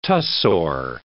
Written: tussore

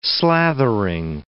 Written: slathering

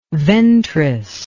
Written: ventris

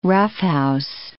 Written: roughhouse

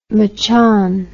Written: machan

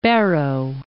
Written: Barrow, Point

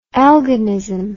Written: elginism

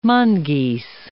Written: mongeese

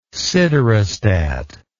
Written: siderostat

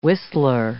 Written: whistler

